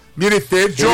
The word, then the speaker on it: Militello: Militelho. Ele falou que a pronúncia do nome dele é como se fosse com H. Militelho.